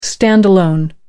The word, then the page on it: stand-alone: American English